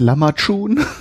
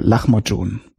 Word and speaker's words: Lahmacun: Viele kennen es vielleicht eher als Lamachun oder Lamachun richtig ausgesprochen heißt es wohl Lachmojun.